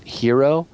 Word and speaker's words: Jiro: who directed this really incredibly moving documentary called I think you would pronounce it Hero. His name is J-I-R-O. So here in America we'd say Jiro, but I think it's pronounced Hero Dreams of Sushi,